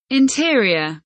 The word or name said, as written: interior